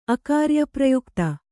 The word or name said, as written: ಅಕಾರ್ಯಪ್ರಯುಕ್ತ